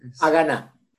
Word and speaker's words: Haganá: que era la Haganá. ¿Lo pronuncié bien? – Haganá.